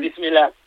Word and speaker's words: Bismillah: Una cosa que me dijo Ilias Akomaz, a ver si la pronuncio bien, Bismillah. – Eso es. – ¿Lo he dicho bien? – Bismillah. Sí, muy bien.